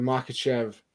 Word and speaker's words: Makhachev: And that would be the Makhachev.